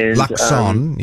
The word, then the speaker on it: Luxon: No T in his name. – Didn't say Luxton. I said Luxton. I did say that. Yeah, I heard a T there. I almost said it wrong.